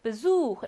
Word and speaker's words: Besuch: Besuch 라고 하는 이 단어는 흐 발음을 내게 됩니다.